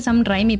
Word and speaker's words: Raimi: Entonces, esta vez vamos a hablar de la primer película de Spider-Man del 2002 de Sam Raimi, o sea, se escribe Sam Raimi, pero se dice Sam Raimi.